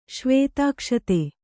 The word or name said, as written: ಶ್ವೇತಾಕ್ಷತೆ